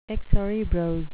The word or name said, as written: excerebrose